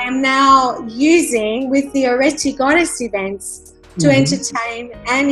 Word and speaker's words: Areti: So I heard of this amazing name called Areti. Am I pronouncing it correctly? – You are. It's actually, in Greek it's Areti, but Areti is an ancient Greek mythology goddess.